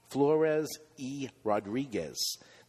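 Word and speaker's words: Flores y Rodriguez: So what do you, how do you say that again? – E. – Flores E. Rodriguez.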